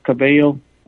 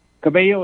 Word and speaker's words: Cabello: Yeah, so everything that I'm seeing down here, I immediately report on X, and you can find me there as Cabello Auden. That's my last name and first name. – Okay, spell that. Spell that for us. – Cabello is C-A-B-E-L-L-O. Auden is A-U-D-E-N.